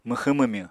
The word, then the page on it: mwxwñ-mwmywñ: Apatani